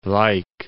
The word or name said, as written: Luik